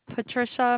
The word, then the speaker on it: Patricia: Patricia McGinnis. – Okay, the first name is spelled P-A-T-R-I-C-I-A.